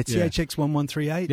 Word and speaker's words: THX 1138: Yeah, THX 1138.